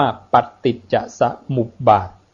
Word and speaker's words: ปฏิจจสมุปบาท: ปฏิจจสมุปบาทนะมอุปมุปบาลีจะมีจุดใต้ปอปาต้องอ่านว่าปฏิจจสมุปบาท